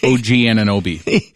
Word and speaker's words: Anunoby: And I guess clearly I was not watching this, but tell me how you say his name. OG Anunoby. Anunoby. – You nailed it. OG Anunoby.